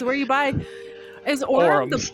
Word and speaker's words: Aurum: I believe I'm pronouncing that right. Aurum Store. A-U-R-U-M. Aurum. Aurum.